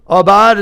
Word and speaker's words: abad: The word tend is abad in the Hebrew. Abad is how it's pronounced.